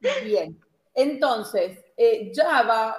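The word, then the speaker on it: Java (חַוָּה): Eva, que en hebreo se pronuncia Java.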